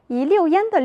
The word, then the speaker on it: 一溜烟: 在这些字中有一个多音字六，在字典中它有两种读音，当它表示量词，用于成行列的事物时，读四声六。比如课文中一溜烟的溜做量词，表示一排一道，在文中形容孩子跑得很快，转眼就消失的样子。